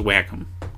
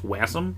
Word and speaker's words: Wacom: It's W-A-C-O-M, which I assume is pronounced whack-a-m. That's what it looks like to me. I don't think the C is like an S-C. I don't think it's wasm. That doesn't make sense.